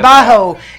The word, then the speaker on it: Trabajo: And that was Trabajo. Did I say it right? Yeah. Trabajo. Yeah.